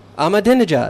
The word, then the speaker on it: Ahmadinejad: Ahmadinejad. – Ahmadinejad.